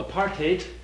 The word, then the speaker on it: apartheid: So apartheid, as we mentioned before, is pronounced that way to rhyme with hate.